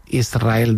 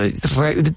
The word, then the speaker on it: Israel: Israel, ¿no? – Y la S con la R es difícil de pronunciar. La gente dice Israel. Es sin verdad. Que suene la S, Israel, sí, es difícil pronunciarse.